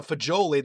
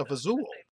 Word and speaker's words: fagioli: It's a lot of people in Hoboken are from Calabria, and Calabrians apparently pronounce like pasta fagioli. They say pasta fazool.